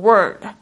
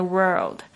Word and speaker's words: world: 都发不好这个字，为什么呢？因为它有一个二这个卷舌音，加上这个呃这个翘舌音哦，所以呢，大家就是在发音上就是常会念错，会念成 word。其实呢，你注注意听一下，其实应该是 world，world，OK，而不是 word。 To live is the rarest thing in the world.